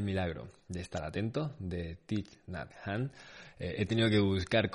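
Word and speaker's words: Thích Nhất Hạnh: así que no quería liarme mucho y por lo visto es Thich Nhat han.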